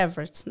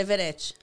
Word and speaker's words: Everett: Na, na, verdade, a pronúncia é Everett, né? Everett. – Daí os brasileiros falam Everett.